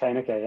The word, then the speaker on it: Heyneke: That's it, Josh Heyneke, yeah.